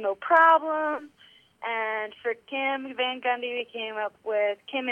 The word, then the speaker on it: Van Gundy: Van Gundy only rhymes with Ted Bundy in my mind, so I'm going to steer away from that conversation.